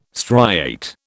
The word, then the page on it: striate: British English